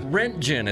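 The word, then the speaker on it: Röntgen: German physicist Wilhelm Rodengarten. – Rodengarten was the police guy in Young Frankenstein with that weird art. – Well, Rentgen is how you pronounce that, believe it or not.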